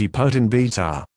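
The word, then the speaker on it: epoetin beta: Neoricorman contains the active ingredient epotin beta, pronounced epotin beta.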